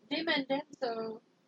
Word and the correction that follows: De Mendoza: Yeah, read that name again. – De Mendoza. Huh? – It's very important that you pronounce that name correctly.